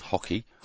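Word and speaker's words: hoci: It's H-O-C-I is pronounced hockey.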